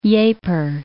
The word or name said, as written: Ieper